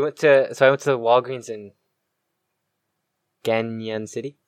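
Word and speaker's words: Cañon: In fact, I went to Walgreens in Ganon City. You have to say it like that because the N with the little squiggly above it. – Oh, it's Ganon then.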